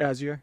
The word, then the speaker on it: Azure: That's how you, how would you pronounce that? – Azure. – Yeah, Azure's supercomputing platform. – As you're saying it. – Azure. Very good.